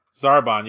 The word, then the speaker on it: Zarbon: but is it Zarbon? – Zarbon, yeah.